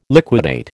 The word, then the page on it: liquidate: American English